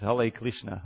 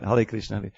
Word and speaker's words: Hare Krishna: Hale Krishna.